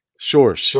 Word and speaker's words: Schorsch: Schorsch.